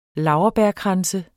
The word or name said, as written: laurbærkrans